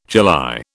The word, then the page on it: July: British English